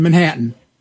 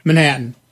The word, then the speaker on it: Manhattan: In Albany, and there is no first A in Manhattan.